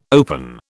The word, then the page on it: open: British English